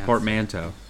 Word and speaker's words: portmanteau: Portmanteau. That's how it's pronounced.